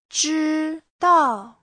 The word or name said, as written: zhi1dao4